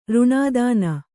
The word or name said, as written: ರುಣಿತನ